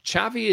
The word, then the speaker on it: Xavi: No, it's in Catalan. It's not. It's the X is a cha, isn't it? – Yeah. – So it's not a za, you know. – Xavi Hernandez.